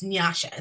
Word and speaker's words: Nyasha: And my name it's Nyasha is how you pronounce it.